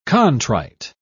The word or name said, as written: contrite